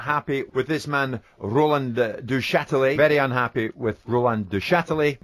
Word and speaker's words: Duchatelet: Actually, Al, stay there because we think we know someone who might be able to help you with the pronunciation of that name. – We're talking about the owner, Roland Duchatelet. Duchatelet.